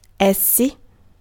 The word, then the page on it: essi: /ˈɛs.si/